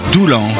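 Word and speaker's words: Doullens: Mais pour le moment, je tiens à vous rappeler que là, le dimanche 13 septembre, je serai avec tous mes musiciens à Doulan, faut prononcer, mais il y a un S à la fin quand même.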